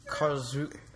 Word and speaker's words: Karlsruhe: How do you pronounce that? – Karlsruhe. – Are you sure? – Positive. My dad's a German.